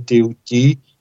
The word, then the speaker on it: Thoth: através de um grande ser chamado Thoth, em egípcio se pronuncia Teuti, egípcio antigo, o deus Thoth, que é conhecido como Hermes Trismegisto, o Hermes três vezes grande.